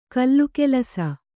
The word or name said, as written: ಘಿಲಘಿಲಿಸು